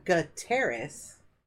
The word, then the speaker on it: Gutierrez: You can't roll your R's, which is okay. Yeah. We Americanized it. So, yeah. Gutierrez. If you can say Terrace, you can say it.